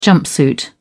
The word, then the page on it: jumpsuit: British English